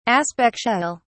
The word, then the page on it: aspectual: British English